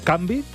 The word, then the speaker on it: canvi: Perquè ha de ser bilabial. – Bilabial. – Com el so de la B, és a dir, ajuntes els dos llavis, l'inferior i el superior. – Canvi. – Canvi. Has de pronunciar-ho amb la M.